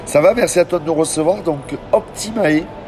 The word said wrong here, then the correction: Optimae: Ouais, on prononce Optimé.